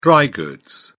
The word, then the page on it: dry goods: British English